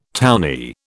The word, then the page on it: townee: British English